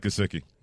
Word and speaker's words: Gesicki: Gesicki? – Mike Gesicki.